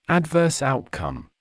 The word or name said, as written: adverse outcome